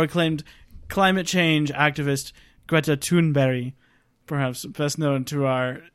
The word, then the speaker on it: Thunberg: It sounds so extra ignorant to hear the way Americans pronounce her name after hearing the correct pronunciation. – Yes, it's Greta Thunberg.